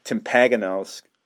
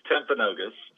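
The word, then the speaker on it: Timpanogos: Oh, well, first of all, you did butcher the name, guys. – Yes. That's why I'm a writer, not a professional audio person. – It's Timpaganos.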